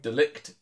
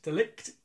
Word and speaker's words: de Ligt: So I'm going for the Netherlands defender, Matthias De Ligt. I don't know how to say his name. – De Ligt. – De Ligt.